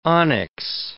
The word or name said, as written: onyx